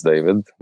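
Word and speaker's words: Djvid: So my name is David, spelled phonetically, which was my parents' idea, wasn't mine. That's why it's D-J-V-I-D. But it's pronounced David.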